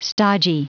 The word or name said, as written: stodgy